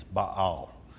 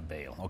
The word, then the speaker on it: Baal: Probably the correct pronunciation is Baal, but we anglicize it to Baal. Okay, so we might as well call it Baal.